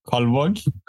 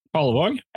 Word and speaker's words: Kalvåg: That's uh, Cal- Calvag. Huh? Calvag. – Cal Cal Calvog. Calvog. Nice. K A L V A G. Kalvåg. Yeah, Kalvåg.